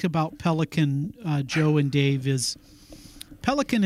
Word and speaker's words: Pelikan: I think it's important, Joe, to come back to a quote by Yaroslav Pelikan. His book, The Riddle of Roman Catholicism, his name is spelled P-E-L-I-K-A-N. Pelikan, in his, I think, very fascinating book, I really like his book, The Riddle of Roman Catholicism.